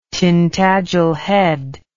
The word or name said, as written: Tintagel Head